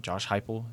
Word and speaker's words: Heupel: And Josh Heupel, is that how you pronounce it? – Yeah.